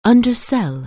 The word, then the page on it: undersell: anglais